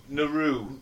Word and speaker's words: Nauru: Nauru. – Nauru. – Nauru. – Nauru. You know, I've heard that so much in this week, and I still can't do it properly. Nauru. – Nauru.